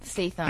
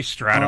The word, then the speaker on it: Statham: I can never say it right. – Statham. – I always say Stratum.